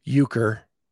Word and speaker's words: Euchre: And you're going to have to a Ukraine tournament. Is that right? Am I saying that right? – Euchre.